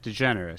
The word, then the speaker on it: DeGeneres: How do you say her last name? – DeGeneres. She said it. – DeGeneres. Okay. I'm always worried that I'm saying like degenerate, and I'm not here to insult anybody, even Ellen.